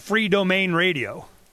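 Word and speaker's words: Freedomain: You know, how do you say that? I call it free domain radio. – That's perfect. That's just great, because it's freedom is the main thing, and you want to be free in your own domain.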